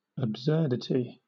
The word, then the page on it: absurdity: British English